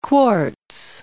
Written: quartz

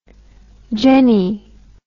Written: genny

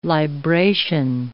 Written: libration

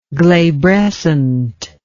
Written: glabrescent

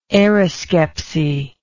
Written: aeroscepsy

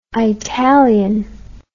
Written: eyetalian

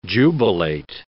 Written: jubilate

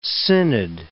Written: synod

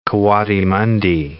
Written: coatimundi

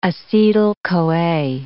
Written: acetyl CoA

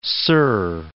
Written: Big Sur

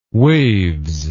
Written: waves

